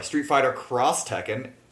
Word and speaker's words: Street Fighter X Tekken: So, but yeah, that's Street Fighter X Tekken, Street Fighter Cross Tekken. Hey, I finally might get the name right.